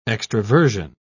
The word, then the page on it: extraversion: British English